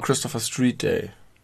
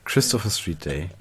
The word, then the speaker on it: Christopher Street Day: Und deswegen müsste es eigentlich Christopher Street Day heißen und nicht Christopher Street Day. Die Betonung muss auf Christopher liegen, denn man sagt es bestimmt nicht, ich fahre nachher noch in die Christopher Street.